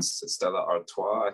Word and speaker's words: Stella Artois: And fun fact about her, she's actually named after the beer brand Stella Artois, I think is the proper pronunciation.